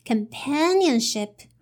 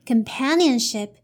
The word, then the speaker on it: companionship: And the stress is here, so you have to raise your intonation here. So it's companionship, companionship. Okay, now let's see the third part.